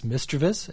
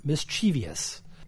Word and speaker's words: mischievous: And then we get a voicemail from English teacher Corinne on the correct pronunciation of the word mischievous. It's mischievous and not mischievous.